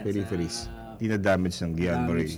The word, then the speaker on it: Guillain-Barré: G-U-I-L-L-A-I-N-B-I-R-R-E. – Guillain-Barré syndrome. Mm. Tinanong ko sa nanay ko, di pari pronounce eh. Sabi niya, Guillain-Barré, kasi pangalan ng tao yan.